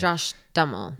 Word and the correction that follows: Duhamel: I don't think Dummel's right. – Josh Duhamel.